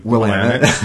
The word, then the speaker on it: Willamette: We taught them how to pronounce the name Willamette.